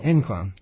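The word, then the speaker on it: ImClone: I am clone. I am C clone? – In clone. In clone. That's how you say it, right.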